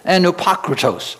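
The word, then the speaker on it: anupokritos: Anupakritos, and what it means is to be without pretense. You see, Anupakritos.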